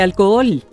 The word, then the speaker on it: alcohol: Don Carlos indica: esta palabra contiene un hiato porque las dos OEs son vocales abiertas, así que se deben pronunciar las dos. – Entonces se dice alcohol.